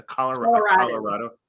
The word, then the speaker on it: Coloradoan: I did want to ask you, being a Coloradoan, is that how you say it? A Coloradoan. Mm-hmm.